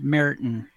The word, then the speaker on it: Marathon: It's actually pronounced Meriton. – Yeah. – It's pronounced wrong.